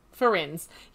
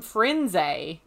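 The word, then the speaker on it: Firenze: How would you pronounce the main centaur's name? – Ferenz. – Ferenz. Yep. Me too. But except for Stephen Fry calls him Frenze. And yes. I'm like, wait, who the hang is Frenze? – Frenze.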